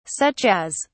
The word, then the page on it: such as: British English